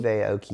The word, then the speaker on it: Aoki: It was almost as packed as the, how do you say that? Aoki? – Steve Aoki.